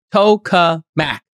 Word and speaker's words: tokamak: Here's how you pronounce it. Tokuhmak. Tokamak.